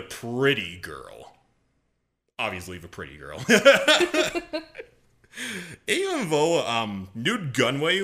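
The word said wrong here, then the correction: Nute Gunray: Even though Newt Gunray was a prominent member of the Separatists, the Trade Federation was proclaimed neutral within the Clone Wars.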